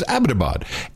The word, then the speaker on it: Abbottabad: It's Abbottabad. Abbottabad. Abbottabad. Not Abbottabad. – Yeah, Abbottabad. Okay, so Abbottabad. – From now on, official pronunciation is Abbottabad.